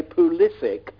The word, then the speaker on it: Pulisic: From Dortmund, who keeps on telling, I think you got his name right, but he keeps on telling us to say Pulisic because... – Pulisic? – Yeah, Pulisic. – Because that's what they call him in Hershey, Pennsylvania, where he grew up.